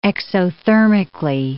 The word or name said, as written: exothermically